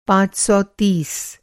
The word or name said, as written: पाँच सौ तीस